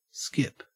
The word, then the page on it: skip: Australian English